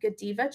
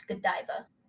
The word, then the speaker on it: Godiva: Robert had bought a box of Godiva chocolates, and a handwritten note was included. – Did you just say Godiva? – Yeah, is that how you say it? – It's Godiva.